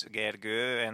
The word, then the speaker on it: Gergő: My name also has like two lines on top of the O. It's Gergő, and most of the people I talk to can't really pronounce it.